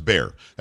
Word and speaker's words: Bexar: By the way, nationally, it's B-E-X-A-R. Don't say Bexar. It's Bear. That's how we pronounce it here.